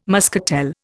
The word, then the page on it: muscatel: Indian